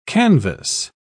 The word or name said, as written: canvas